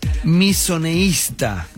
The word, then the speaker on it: misoneísta: ¿Qué es misoneísta? Misoneísta. Con acento en la segunda I.